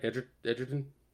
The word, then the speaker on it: Edgerton: Edgerton? – Mm-hmm.